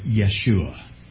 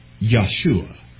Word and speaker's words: Yeshua: And so very few actually would pronounce it Joshua, but it's going to be Yahshua, or perhaps you have heard Yeshua. That's the Hebrew name, Joshua, as we say it. In Greek, that becomes Iesus, which we pronounce Jesus. Again, we just love that soft J, apparently.